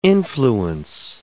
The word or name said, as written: influence